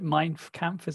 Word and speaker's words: Mein Kampf: Like you know, Hitler's autobiography, the Mein Kampf is how you, how you say it.